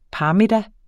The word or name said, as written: parmiddag